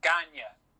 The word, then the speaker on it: Gagne: So what's funny is, so his name is spelled Gagne. – Yeah. – And it's Ganya. One of the lads from The Last Fan always calls him Gagne.